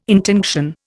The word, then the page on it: intinction: Indian